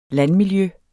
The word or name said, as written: landmiljø